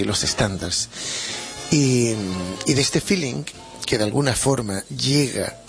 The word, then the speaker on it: filin: Tres o cuatro temas de feeling. Y feeling, claro, y se escribe tal cual, F-I-L-I-N, feeling, tal cual como se pronuncia una palabra anglosajona, feeling, que significa sentimiento.